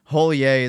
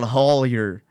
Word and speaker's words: Hollier: He's one of them New Orleans area, Southern Louisiana French motherfuckers who go by the French pronunciation, but because they're eating crawdaddies, they're definitely just called Hollier.